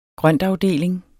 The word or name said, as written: grøntafdeling